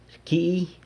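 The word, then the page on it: rcyii: San Lucas Quiaviní Zapotec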